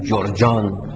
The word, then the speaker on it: جرجان: مثلا ببینید، ما گرگان را میگیم جرجان، جرجان. گاف را در عربی نوعاً به جیم تلفظ میکنن، گرگان جرجان، گرگانی جرجانی، سید میر شریف الدین جرجانی، درست شد؟